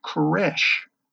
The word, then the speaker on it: Koresh: And Cyrus, in Hebrew, is pronounced Koresh.